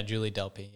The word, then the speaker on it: Julie Delpy: Julie Delpy. Am I saying her name right? Yeah, Julie Delpy.